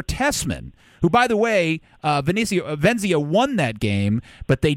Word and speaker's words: Venezia: Venezia.